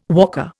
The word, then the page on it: walker: Indian